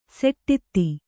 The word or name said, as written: ಸೋಡ್ತಿ